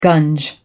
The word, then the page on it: gunge: American English